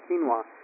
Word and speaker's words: quinoa: Quinoa. Sorry, I knew as that came out. I'm like, I'm not pronouncing that right. Quinoa.